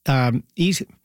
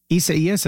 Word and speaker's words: Isaias: It's pronounced Isaias. Isaias. It looks like Isaias. Isaias, I believe, is how you pronounce that weather system.